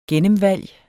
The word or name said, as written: gennemvalg